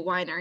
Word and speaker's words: Weiner: Is it Weiner? – Yes, thank you, Weiner.